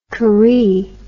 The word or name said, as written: karee